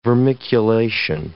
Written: vermiculation

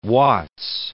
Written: Watts

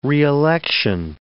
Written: reelection

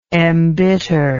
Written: embittered

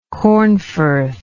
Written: Cornforth

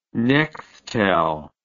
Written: Nextel

